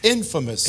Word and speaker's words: infamous: You said infamous. It's pronounced infamous. – It's not famous and infamous? – No, infamous is the way it's pronounced.